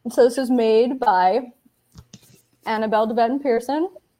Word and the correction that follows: Peterson: Peterson? Why do I always say Pearson? I always like skip a syllable on her name.